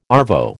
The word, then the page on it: arvo: American English